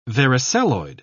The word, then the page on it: varicelloid: British English